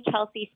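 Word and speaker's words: Kalsey: So you just type in Love Me Like an Outlaw, and my name is K-A-L-S-E-Y-K-U-L-Y-K. And you can find me on social media as well under that name. I'm the only Kelsey spelt with an A, so I'm pretty easy to find.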